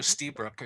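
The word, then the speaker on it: Lstiburek: I think you forget the L and you just go Stiebrick or something like that.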